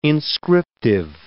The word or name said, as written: inscriptive